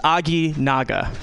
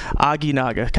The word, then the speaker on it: Aginaga: It's Aginaga. Aginaga. Kind of sounds Japanese, right?